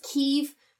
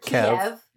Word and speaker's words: Kyiv: I hear it pronounced Kyiv, but I've also heard Kiev.